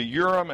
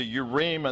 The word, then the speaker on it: Urim: That's how I've usually heard these words pronounced in English. Hebrew has a tendency to want to stress the final syllable. So in Hebrew, this would really be the Urim and the Thummim.